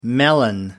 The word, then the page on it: melon: American English